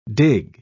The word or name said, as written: dig